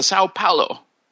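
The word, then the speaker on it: São Paulo: We see the registry and find out that it's the Sao Paulo, which I probably mispronounced.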